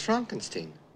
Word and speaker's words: Frankenstein: No, it's pronounced Frankenstein.